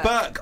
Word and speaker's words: berk: People say Burke. I Google it. Nah, Google it. If how do you spell it? B-U-R-K? – B-E-R-K?